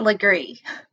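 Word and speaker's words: Lagree: It's pronounced LeGras, right? – It's Legree. Legree. But that's a fancy way of saying it.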